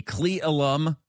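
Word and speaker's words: Cle Elum: Now, if you remember from yesterday, there was a 10-year-old girl from Federal Way who was lost at the Cleelum River.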